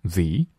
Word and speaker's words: the: 那为什么 the 会变成 z 呢？因为后面呢是元音， the 后面遇到元音的时候，它的发音会发生变化，会变成 z, the air。后面还会遇到，到时候我们再详细讲解。